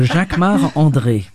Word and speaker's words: Jacquemart-André: Jacquemart-André. Jacques Arnaud, you say it like André. A Frenchman. – Jacques Marc. Oh, because I'm French. So Jacques Marc André.